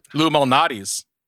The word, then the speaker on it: Malnati's: Once when Grace was pregnant and she was about to give birth, and at the beginning of quarantine, he sent me Lou Malnati's. Is that how you pronounce it? – Lou Malnati's.